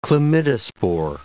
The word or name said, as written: chlamydospore